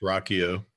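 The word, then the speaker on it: Ruocchio: I know your own Chris, I can never pronounce his name. Rocchio? – It's Rocchio.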